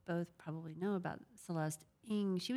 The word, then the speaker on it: Ng: The last name is NG. And what I love about her Twitter account is it's pronounced underscore Ng, I-N-G. So it's, she tells you it's Celeste Ng.